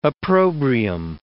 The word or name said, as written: opprobrium